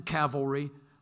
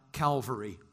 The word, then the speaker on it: cavalry: And I, Hazael, killed 70 kings with lots of chariots and cavalry, which, the, by the way, Hollywood, they pronounce that Calvary all the time. Listen for it. It's fascinating. I laugh every time it happens. Called a Calvary.